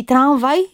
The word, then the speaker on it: tramwaj: Tam, gdzie jest grupa samogłoska ustna, spółgłoska nosowa, w wymowie może pojawić się tak zwana wtórna samogłoska nosowa. Mówimy na przykład sens, prawda? Więc i tramwaj jest także dopuszczalna.